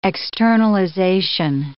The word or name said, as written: externalization